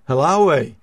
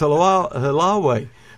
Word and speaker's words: Haleiwa: H-A-L-E-I-W-A, Hawaii. Do you know how to pronounce that? – Halawai. – Okay. It's Halawai.